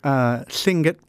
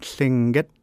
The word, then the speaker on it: Tlingit: So Tlingit people, that's the official way to say it, Tlingit.